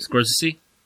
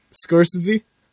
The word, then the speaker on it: Scorsese: Scorsese? – Scorsese's agent. – Scorsese? – Yeah, that's how you actually say his name.